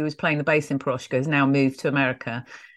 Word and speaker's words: Piroshka: And, of course, Miki, you've already mentioned Blush and your current band, Piroshka.